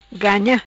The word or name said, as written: Ganha